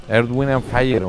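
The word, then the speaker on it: Earth, Wind & Fire: ¿Te gustó la pronunciación o no tanto? – Wind and Fire. Perfecto. Tienes, Emilio, la pasa 3 veces por programa al grupo. Su opinión. – Earth, Wind and Fire, hombre.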